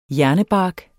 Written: hjernebark

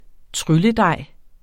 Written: trylledej

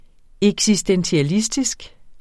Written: eksistentialistisk

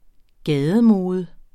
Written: gademode

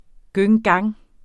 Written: gang

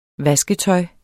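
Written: VASKETØJ

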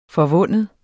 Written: forvinde

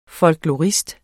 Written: FOLKLORISTER

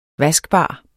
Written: vaskbar

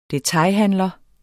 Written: detailhandler

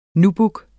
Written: nubuck

